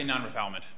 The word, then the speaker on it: non-refoulement: If your goal is to avoid running, I don't know how to pronounce that word, is it refoulement? – I think it's like scientia, Your Honor. Everyone has their own approach. – How do you say it? – I say non-refoulement. – Okay, refoulement. Okay.